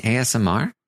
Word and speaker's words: ASMR: He's like, "I'm doing some asthma right now with my chips," and Day's like, "What? – Do you mean ASMR?"